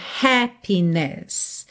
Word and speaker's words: happiness: Então, por exemplo, em happy, a sílaba tônica é a primeira. Em happiness, a sílaba tônica continua sendo a primeira. Mas agora você tem uma última sílaba que também tem ali o seu peso, tá?